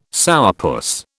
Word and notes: sourpuss: British English

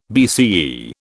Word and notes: BCE: British English

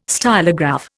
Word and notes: stylograph: Indian

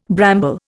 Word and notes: bramble: Indian

